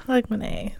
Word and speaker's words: Hogmanay: Hogmanay? – Hogmanay.